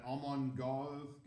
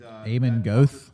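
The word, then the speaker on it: Amon Göth: And I gotta go tie with that Amon Goth guy, however you say his name, Schindler's List. – Oh. – And Amon Goth. – And that, is that how you say his name? – Yeah, I think so.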